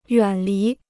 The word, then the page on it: 远离: yuǎn lí